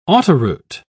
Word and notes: autoroute: British English

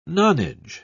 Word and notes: nonage: British English

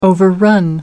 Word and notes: overrun: American English

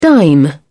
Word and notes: dime: British English

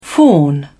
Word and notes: fawn: British English

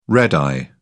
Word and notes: red-eye: British English

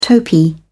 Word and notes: topee: British English

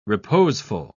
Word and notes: reposeful: British English

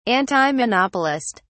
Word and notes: antimonopolist: British English

